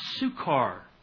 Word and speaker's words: Sychar: In fact, in the Greek here, it's pronounced Sukar.